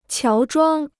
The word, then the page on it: 乔装: qiáo zhuāng